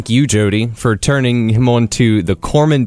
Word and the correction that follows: Cormoran: Or I don't think I said that right. – Cormoran. I think that's Cormoran.